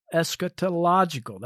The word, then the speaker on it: eschatological: about eschatological events, eschatological, that's how you say it,